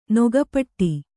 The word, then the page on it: ನಗುಪಾಡು: kannada